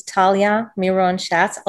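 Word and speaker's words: Talya Miron-Shatz: So my name is Talya Miron Shatz. I'll spell it because it's not obvious. T A L Y A M I R O N hyphen S-H-A-T-Z.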